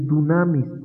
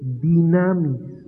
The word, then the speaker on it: dunamis: Dice dunamis, es dinamis. Porque no decimos dunamita, ¿verdad? Decimos dinamita, no decimos upopótamo, hipopótamo.